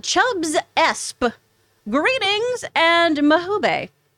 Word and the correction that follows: Mabuhay: Did I say that right? Jay and crew, coming to you live from Manila and been a fan since... – It's Tagalog. – Oh, it is? – Mabuhay is it's Tagalog, yes.